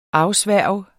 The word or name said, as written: AFSVOREN